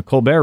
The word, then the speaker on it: Colbert: That will include not only snippets of the original Comedy Central shows like The Daily Show and The Colbert Report, good job with the pronunciation. – Well, those are actually limited what they can rebroadcast. – It's French.